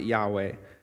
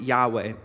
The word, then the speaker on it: Yahweh: That is whenever the word Lord is representing this covenant name of God, this Yahweh, Y-H-W-H. We typically pronounce it Yahweh, and this is the covenant name of God revealed to Moses back in Exodus 3, verse 14, back when God called Moses to the burning bush and said, I am the Lord. I am has sent me to rescue the people. We typically, like I said, pronounce it Yahweh, but being completely sure of its pronunciation is lost to history.